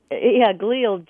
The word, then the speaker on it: glial: You pronounce it glial cells? – Glial, G-L-I-A-L.